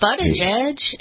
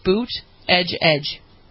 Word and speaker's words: Buttigieg: Not Buttigieg. It's Buttigieg. – Right. Boot. – Say it like it's Trump, because Trump had trouble with the two. Boot, edge, edge.